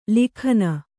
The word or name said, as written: ಲೇಖನ